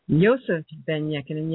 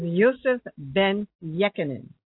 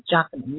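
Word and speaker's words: Yosef ben-Jochannan: Yosef Ben-Yekinen, yes, that's the correct pronunciation, because he told me. His name is Yosef Ben-Yekinen. It's spelled J-O-C-H-A-N-N-O-N, but it's not Jochanan, Yekinen.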